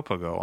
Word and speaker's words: Pagot: Yes, you have to pronounce Pagot. – Pagot. – If you want to speak in Provençal. – Good thing I asked you. – And if you want to speak in French, you say Pagot.